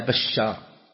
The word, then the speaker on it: بشار: وذلك شبه الآن الحافظ بن حجر رحمه الله في فتح الباري يقول حدثنا بشار بفتح الباء وتشديد الشين بعدها ألف ثم راء، لو أنه قال باء شار بالتشديد ما تنضبط، لأنه يعني مع اختلاف النسخ.